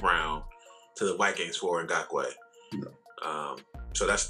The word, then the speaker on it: Ngakoue: Yeah, Ngakoue, if that's how you say his name. – Yeah, Ngakoue.